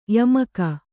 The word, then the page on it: ಯಾಮಿಕ: yāmika